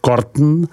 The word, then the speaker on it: Corton: Mezi víny převládají vína barvy červené, vyrobená z odrůdy Pinot Noir, pouze nedosahující s výjimkou vína Corton, vyslov Corton, těch nejvyšších jakostních statutů pro burgundské vinařství.